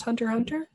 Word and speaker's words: Hunter x Hunter: Yeah, it's generally typeset Hunter x Hunter, but I believe it's pronounced Hunter Hunter. Every single episode has an X between every word in the episode title. Yeah. And I doubt that you're supposed to pronounce those.